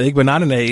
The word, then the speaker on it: Igbinoghene: Igbinoghene is a outstanding defensive cornerback for the Auburn Tigers.